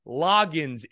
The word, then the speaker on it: Loggains: who I keep messing up this guy's name, by the way. For whatever reason, I keep butchering his name. I've been saying Dowell Goggins. I've said Roggins, Loggains, whatever. Dowell Loggains is his name.